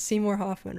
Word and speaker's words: Seymour Hoffman: And then, I want to make sure it's Seymour Hoffman, right? – Yes.